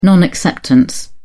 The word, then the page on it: non acceptance: British English